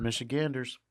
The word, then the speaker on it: Michiganders: Yeah, he also reminded Michiganders, is that how you say it, producer? – That is how you say it, Michiganders.